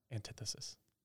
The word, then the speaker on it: antithesis: Yeah, it's like antithesis. Antithesis. – Antithesis. – Yep. – Just like that? – Yeah. – The more you learn. – Yeah. I've been pronouncing it wrong. It's antithetic. – Yeah, like that.